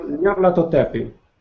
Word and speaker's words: Nyarlathotep: ou pelo menos Nyanlotep. Eu não sei pronunciar, Nyanlotep. Nyanlotep. – Nyarlathotep.